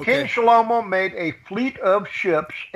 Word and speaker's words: Shlomo: Shlomo is Solomon, okay? But I use the Hebrew pronunciation.